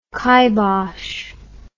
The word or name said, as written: kybosh